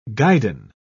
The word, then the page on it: guidon: British English